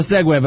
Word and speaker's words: segue: So it's called a segue, but I pronounce it Segui.